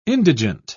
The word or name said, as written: indigent